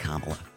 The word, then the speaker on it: Kamala: Except, like, it's pronounced Kamala.